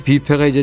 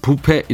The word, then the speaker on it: 뷔페: 이게 1980년대 초반 이때 비페가 이제 지금처럼 대중화됐을 때가 아니니까요. 비페 이 발음 굴리기가 어색해서 그런지 그때는 부패 이렇게도 했죠.